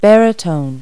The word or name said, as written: baritone